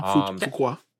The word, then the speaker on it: Fuqua: But it feels like maybe because someone, I mean, I know that Antoine Fuqua, I butchered that. I'm sorry. – Yeah, no, Fuqua. – And you said it right.